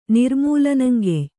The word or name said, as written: ನಿರ್ಮೂಲನಂಗೆಯ್